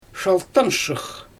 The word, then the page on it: ʃaltanʃɯx: Xyzyl